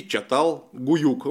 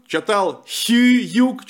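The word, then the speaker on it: Çatalhöyük: Думали, что евреи уникальные, но в 60-х годах в Турции нашли город, э, который находится теперь в местечке Чатал-Гуюк. Он по-разному пишется. Чатал-Гуюк, Чатал Гююк, Чатал Хююк через два Ю. Э, в общем, выберите то, что вам нравится. Это, в конце концов, э, русское произношение из турецкого языка.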